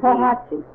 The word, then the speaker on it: Podhajce: The name of the town in Poland is P-O-D. – Pohatchy. – Spell it on there and then it'll be P-O-D-H-A-J-C-E.